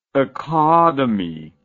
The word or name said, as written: akademi